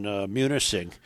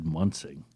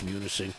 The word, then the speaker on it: Munising: I've always said Munsing. not knowing. – I think it's Munising.